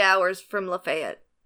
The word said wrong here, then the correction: Lafayette: Why do you say it so bad? It hurts. – Lafayette, is that better? – Yes, that's so much better. Lafayette. You're putting the wrong emphasis on the wrong syllable.